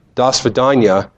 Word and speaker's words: до свидания: The last one, goodbye, до свидания, is five syllables to say goodbye in Russian.